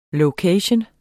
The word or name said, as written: location